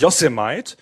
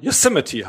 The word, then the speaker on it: Yosemite: Ich habe ja auch lange gebraucht, bis Apple die neue Betriebssystemversion rausgebracht hat, dass es nicht Yosemite, sondern Yosemite heißt, diese Gegend da in Amerika.